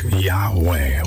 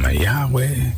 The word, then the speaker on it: Yahweh: This name in itself, God's name is almost always translated Lord, all caps, in the English Bible, but the Hebrew word be pronounced something like Yahweh, Y-A-H-W-E-H, and it's built on the word I am.